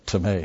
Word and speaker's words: timē: I think it looks like time, doesn't it? But it's timē. It should be a long E over the... In the Greek, it's timē.